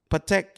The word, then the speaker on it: Patek: Is a how's it pronounced? Patek? – Patek, yeah.